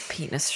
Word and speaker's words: pinnace: It's a penis ship. – If you Google it and then it gives you the pronunciation, it'll say it.